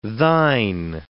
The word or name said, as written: thine